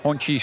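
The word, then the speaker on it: Hongqi: Dus we hebben Honky, Xpeng, BYD. Honchi, sorry. Ja, zeg ik het weer verkeerd.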